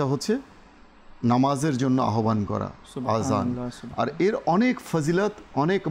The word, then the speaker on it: আজান: তো খেয়াল রাখতে হবে আমরা যেন আজান ব্যবহার করি, তো এটা সঠিক উচ্চারণ এটি।